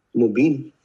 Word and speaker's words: Mobeen: Mobeen.